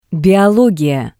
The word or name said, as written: Biologiya